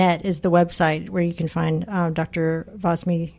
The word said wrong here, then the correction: Vamsi: Am I saying that correctly? – Vams- Vamsi, Vamsi, Krishna.